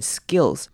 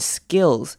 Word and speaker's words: skills: Knowledge through knowledge and skills. Skills 呢，这边还记得吗？S K 开头的字我们会念成 s g 的声音，也就是念成 skill，会念成 skills 而不是 skills。